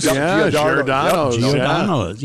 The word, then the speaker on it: Giordano's: Giordano's. – Giordano's.